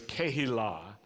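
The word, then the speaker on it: kehilah (קהילה): And one of the ways that we say community in Hebrew is kehilah, which also means congregation. So two good words to know, mishpacha, say that with me, and kehilah.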